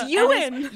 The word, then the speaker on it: Ewan: Oh yeah, I'd like to issue a formal apology to all of our Scottish fans who slammed my mentions saying it's Ewan McGregor, not Ewan McGregor. – No, it's Ewan.